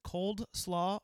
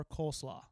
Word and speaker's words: coleslaw: You pronounce it cold slaw or coleslaw? – It's never pronounced cold slaw by any human being. That's incorrect. That's something a child would say.